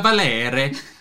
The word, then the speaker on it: Valere: And we get the Horn of Valer. Or Valir. Or Valere. The Horn of Valere.